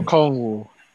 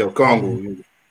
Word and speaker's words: Okongwu: Onyeka Okongwu. – Yeah, Okongwu.